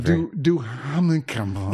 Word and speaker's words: Duhamel: Duhamel. – It's pronounced Dumas. Josh Dumal. – it's Dumas.